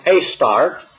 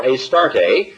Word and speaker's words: Ashtaroth: or Ashtaroth with a silent H and pronounced Astart or Astarte by the ancient Sumerians, Babylonians, Zidonians, and then eventually by the Egyptians and the Greeks.